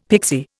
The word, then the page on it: pixie: Indian